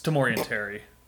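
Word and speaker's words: Tamorrion Terry: For our listeners, I'll correct Kunze's pronunciation on the black guy. It's Tamorian Terry.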